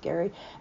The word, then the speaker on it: Gerry: Don't forget to look for Gary All Things Cruise, G-E-R-R-Y, but yes, it is pronounced Gary, and Splash Magazine Worldwide.